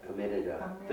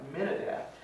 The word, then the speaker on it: Amminadab: Amminadab. – There you are. Amminadab.